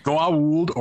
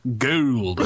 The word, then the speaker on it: Goa'uld: Do you ever wonder, like, you can divide the Stargate universe into people who say Goa'uld with, like, three syllables and those who say it with one syllable? – That's true. – Like, it's either Goa'uld or Gould.